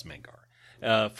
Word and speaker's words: Mangara: So the next card is Mangara the Diplomat, or Mangara, depending on where you do the syllable breaks. No, it's Mangara.